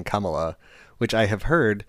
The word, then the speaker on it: Kamala: Anyway, she writes, Kamala rhymes with Mamala.